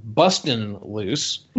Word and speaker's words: Bustin' Loose: And then, and then he did a movie called Busting Loose from 1981. Well, I said it wrong. Bustin' Loose.